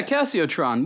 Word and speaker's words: Casiotron: Yeah, Casiotron.